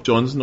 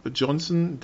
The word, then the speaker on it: Johnson: Also der Herr Johnson heißt Jakob Johnson und legt Wert drauf, dass es übrigens deutsch ausgesprochen wird.